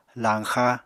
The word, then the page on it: lañxa: apatani